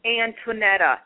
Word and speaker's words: Anntonetta: That's what I was going to yes, my name is Antoinetta. – Okay, could you say your name for me one more time? – Antoinetta. A-N-N-T-O-N-E-T-T-A.